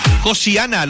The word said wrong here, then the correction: Yoshiana: Oye, ¿cómo se dice? ¿Es Yoshiana? Sí, Yoshiana.